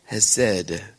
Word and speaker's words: chesed: Remember? Hesed. Remember? C-H-E-S-E-D. Has said, the sea is silent. Mercy in Hebrew, has said, is a God word.